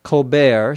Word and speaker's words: Colbert: Colbert, C-O-L-B-E-R-T, is the name of the person who is the host of the show. He pronounces his name Colbert. You don't pronounce the T. So kind of as a joke, you also pronounce report, R-E-P-O-R-T, as rapport, so that it rhymes with Colbert.